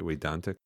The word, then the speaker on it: Vedantic: It's spelled with a V, but right. in India that's pronounced as a W. But in the West, we tend to say Vedic or Vedantic because otherwise people get confused.